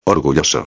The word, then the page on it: orgulloso: espanhol